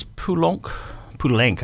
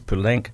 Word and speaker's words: Poulenc: Moving on about 90 years ago, and Francis Poulenc, I should say, that's the proper pronunciation. Francis Poulenc, French composer.